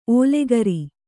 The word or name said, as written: ಓಲೆಗರಿ